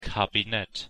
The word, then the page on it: kabinett: German